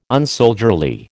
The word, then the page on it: unsoldierly: American English